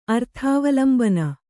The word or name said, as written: ಅರ್ಥಾವಲಂಬನ